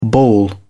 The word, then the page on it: bawl: British English